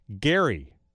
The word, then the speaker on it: Gerry: Eldridge Gerry.